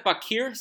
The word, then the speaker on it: Bachiyr: Now, am I pronouncing this right? Is this the Bakir series? – Yes, the Bakir series.